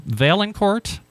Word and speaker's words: Vaillancourt: Jason, now, correct me if I pronounce this wrong, Valancourt? – That's correct. – Oh, I did that right.